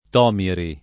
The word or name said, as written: Tomiri